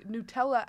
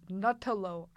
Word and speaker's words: Nutella: But my mom will say Nutella as Nutello.